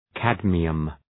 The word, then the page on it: cadmium: ‘kædmıəm